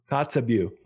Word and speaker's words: Kotzebue: He is a doctor at the hospital and emergency room in the Arctic community of Kotzebue. Is that correct? Am I saying it right? – Kotzebue.